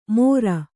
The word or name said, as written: ಮೊರ